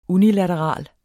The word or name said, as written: unilateral